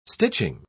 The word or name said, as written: stitching